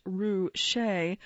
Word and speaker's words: Ruscha: Ruscha, to quote the business card he once passed around, which has marked on it pronounced Ruscha,